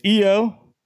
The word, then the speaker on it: Io: So a lot of people apparently thought her name was Lo, and this was maybe the first time they'd heard her name pronounced out loud. No, it's Io, I-O, but pronounced Io.